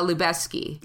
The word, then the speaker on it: Lubezki: It's Emmanuel uh, Lubezki.